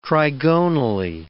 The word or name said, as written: trigonally